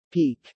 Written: peek

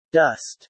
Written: dust

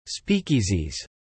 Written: speakeasies

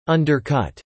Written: undercut

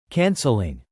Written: canceling

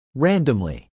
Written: randomly

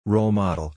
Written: roll model

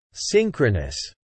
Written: synchronous

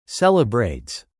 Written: celebrates

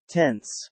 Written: tense

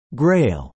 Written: grail

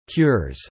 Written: cures

